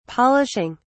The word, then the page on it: polishing: British English